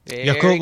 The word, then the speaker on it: Yakult: Yakult, like the yogurt drink.